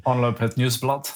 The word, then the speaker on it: Omloop Het Nieuwsblad: Omloop Het Nieuwsblad.